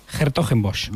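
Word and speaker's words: 's-Hertogenbosch: En Hertogenbosch, pues en Países Bajos, el polaco Marchak ha ganado a Alex de Miñaur. – ¿Lo puedes repetir el torneo holandés, que lo has dicho muy bien? – Hertogenbosch.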